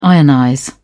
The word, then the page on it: ionize: British English